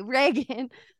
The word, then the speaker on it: Reagan: I keep calling her Regan because I have like a niece Regan. And her name is pronounced Regan. And so I've just wholesale gone on the other side of things. I'm sorry. But anyway, Reagan.